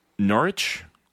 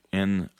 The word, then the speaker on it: Norwich: Norwich? – Yes. – Norwich, Norwich.